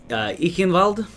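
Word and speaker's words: Eichenwalde: Ichenwald? – Sure. – Yeah, because I'm assuming this is German, so the W would be a V, right? – Kind of.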